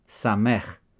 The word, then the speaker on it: Samech: Lesson 29, the Hebrew letter Samech, pronounced S as in sun.